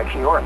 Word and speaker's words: Kia ora: And I noticed when the guys in Everclear said Kia ora, mate, you guys warmed up in that. That's a Maori greeting, right? – Yeah, no, the correct pronunciation.